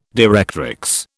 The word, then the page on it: directrix: British English